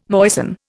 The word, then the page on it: moisten: Indian